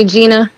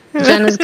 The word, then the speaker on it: Jena: Everybody calls me Gina. Jenna's good.